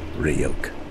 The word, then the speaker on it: Ryuk: Hey, kid. It's pronounced Ryuk.